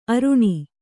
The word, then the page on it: ಅರಣ: Kannada